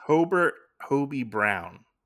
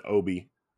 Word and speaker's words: Hobie: His name is Hobie Brown. – Okay. – Yeah, that's his name. – Yeah. Every time I kept hearing some of the other characters say his name, I thought they were saying Obi.